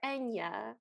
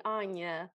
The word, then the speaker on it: Anya: And then he calls her Enya, like the singer, even though I'm pretty sure her name was supposed to be Anya, based on the fact that it's spelled with an A.